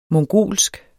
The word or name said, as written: mongolsk